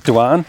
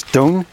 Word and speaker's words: Doan: There's, you know, I, my accent will call it Duan, but I know other people will have it as Doan. – Doan. Okay. How do you spell it? – D-O-A-N. – D-O-A-N.